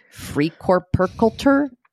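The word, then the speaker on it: Freikörperkultur: They call it FKK, which is Freikorpskultur. And that is their nudist culture. Did I say that right? – That's definitely how it's pronounced.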